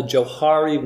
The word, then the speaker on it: Johari: And while I was at the University of Calgary, I learned of something what is called the Johari window. It's spelled J-O-H-A-R-I, Johari window.